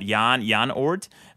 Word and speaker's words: Oort: It's pronounced, what, Jan Oort, because he's from the Netherlands or something like that.